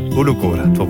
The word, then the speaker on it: Ulukora: Het monument heet trouwens Ulukora, zeg ik dat goed? – Helemaal. – Ulukora, top.